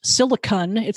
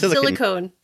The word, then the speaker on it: silicon: So the big news is the Apple silicon chip, and it's pronounced silicon. I guess we knew that, but it seemed like they're very precise about that pronunciation. – I don't like it. I'm not silicon. Silicon. It's not silicone. I don't like. – Not silicon. Not silicone. Silicon.